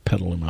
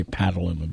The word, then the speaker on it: Petaluma: Petaluma. I always want to say Petaluma, but it's Petaluma.